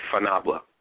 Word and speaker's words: fanabla: There's an expression in Italian called, it's pronounced, well, my grandmother used to say fanabla, which basically means, it's that stupid American-Italian shortening of, it means go to Naples, basically, which is like saying go to hell.